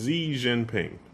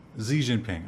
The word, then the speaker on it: Xi Jinping: Is his name Jing or Jian or? – I'm not sure how to pronounce. – Xi Jinping. – Xi Jinping.